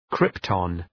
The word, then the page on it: krypton: {‘krıptɒn}